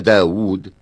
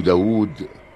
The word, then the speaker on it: داود: إنه لا لزوم في الكتابة العربية أن توافق صورة الرسم صورة النطق باللفظ، فإن داود يكتب بواو واحدة والنطق بواوين، وعمر يكتب بعد رائه واو ولا ينطق بها.